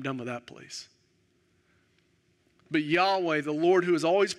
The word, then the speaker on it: Yahweh: Y-H-W-H, the name we would pronounce it, Yahweh.